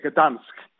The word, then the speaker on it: Gdansk: en Gdansk. Se escribe con G, Gdansk, en la final de la Europa League, con un estadio nuevo con capacidad para 50.000 personas.